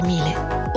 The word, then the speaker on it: Miele: Si scrive Miele Miele si legge Mile.